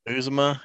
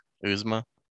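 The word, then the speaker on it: Uzomah: But when did Azuma or is it Azuma? Bengals tight end? – Uzuma, yeah. – Okay, you said it better, I think. Uzuma? – Uzuma. – Okay, yeah, I think that is how you say it.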